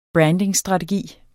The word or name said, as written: brandingstrategi